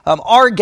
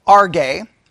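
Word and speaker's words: Arge (ὀργή): He says, wrath, he has the Greek word there, that the transliteration would be spelled O-R-G-E. All right? Or that's how he has it, O-R-G-E. All right? Arge is how it's pronounced. Arge. O-R-G-E is how it has it out.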